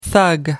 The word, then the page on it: thug: British English